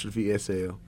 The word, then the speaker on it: Thi'sl: So THI’SL is spelled THI'SL.